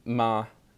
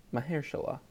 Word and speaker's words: Mahershala: But Slate says his name is pronounced Mahershala. Mahershala.